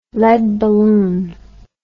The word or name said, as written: lead balloon